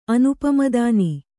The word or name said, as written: ಅನುಪಮದಾನಿ